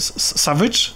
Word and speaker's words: Savage: Savage, tak? On się tak, tak się go wymawia.